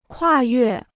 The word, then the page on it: 跨越: kuà yuè